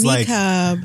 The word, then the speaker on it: niqab: This is niqab. – Like, Is that how you say it? I don't know how. – N-I-Q-A-B.